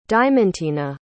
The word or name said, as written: diamontina